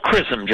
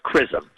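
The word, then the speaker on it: XRISM: Is that CRISM? It's called CRISM. Its spelling is difficult, but it's pronounced CRISM.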